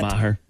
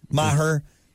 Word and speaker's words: Maher: Maher. – Maher.